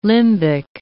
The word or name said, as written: limbic